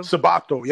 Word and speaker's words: Sabato: Is it pronounced Sabato? – Sabato, yep. It's pronounced Sabato.